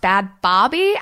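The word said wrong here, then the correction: Bhad Bhabie: and it's actually bad baby.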